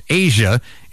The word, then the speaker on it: Aja: Also on this date in 1977, Steely Dan released their sixth album, AJA, pronounced Asia.